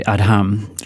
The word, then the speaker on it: Adham: Adham, that's it's Adam spelt with an H in it, so it's actually Adham, tries to beat records for visiting tube stations.